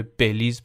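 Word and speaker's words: Belize: بد تلفظ میکنم توی اجرا بیلیز میگم باید مثلا بیشتر نزدیک به بلیز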